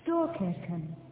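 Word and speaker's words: Storkyrkan: Yesterday, when we visited the great church, you pronounced it Storkirken. – Yes. – Is that the correct pronunciation? – Yes, I think so.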